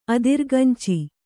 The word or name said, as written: ಅದಿರ್ಗಂಚಿ